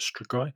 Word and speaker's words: strigoi: The strigoi is that how you pronounce yeah. it? Strigoi. Yeah.